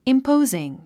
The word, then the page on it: imposing: impóuziŋ; インポォズィン